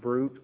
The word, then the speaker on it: bruit: We have one more word to look up here, and that's brute, B-R-U-I-T. Brute, B-R-U-I-T. Pronounced brute, or some may render it bruit. It also be put that way. Now, B-R-U-I-T is report, rumor, or fame.